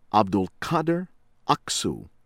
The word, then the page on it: Abdulkadir Aksu: American English; from Turkey